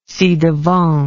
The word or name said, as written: ci-devant